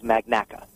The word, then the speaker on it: Magnacca: And um, my last name is Magnacca.